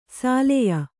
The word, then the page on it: ಸಾಲೆಯ: sāleya